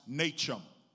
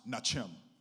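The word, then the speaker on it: nacham: In the Old Testament, there's a Hebrew word that is pronounced nachem. I've even heard it pronounced nachem, which means to turn around.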